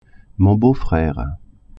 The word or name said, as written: beau-frere